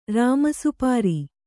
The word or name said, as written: ರಾಮಸುಪಾರಿ